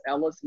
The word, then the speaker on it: Ellis: So Kareem, and that's spelled K-A-R-I-M, middle initial R, last name is Ellis, E-L-L-I-S.